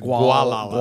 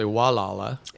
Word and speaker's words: Gualala: Gualala, which is how you actually pronounce. We all pronounce it Gualala. – Gualala. – But it's actually Gualala, and it's like a 17-acre vineyard.